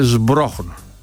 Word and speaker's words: Σμπρόχνο: Εσύ το γράψεις σωστά, αλλά πώς προφέρεται στη Βόρεια Ελλάδα. Σμπρόχνο. Έχουμε και μη μπροστά. Έχουμε σίγμα. Έχουμε πει μη καιρό. Γι' αυτό φτάνουμε στον αριθμό 4.